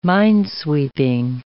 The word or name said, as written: minesweeping